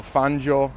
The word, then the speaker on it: Fangio: Probably the greatest motor racing driver or F1 driver of all time, Juan Manuel Fangio, or Fangio, if you pronounce it in alla italiana.